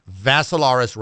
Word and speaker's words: Vassilaris: Last name, Vassilaris, rhymes with Asilaris, but just add a V.